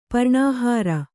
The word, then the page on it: ಪ್ರಾಣಹರ: prāṇa hara